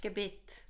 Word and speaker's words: Gebet: So is it Gebet? – Gebet. – Okay, thank goodness. – So like the prayer, let's say.